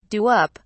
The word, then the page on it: do up: British English